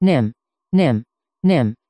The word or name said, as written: nim